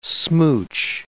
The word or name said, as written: smooch